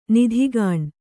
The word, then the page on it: ನೆತ್ತಿಗಣ್: kannada